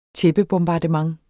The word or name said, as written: tæppebombardement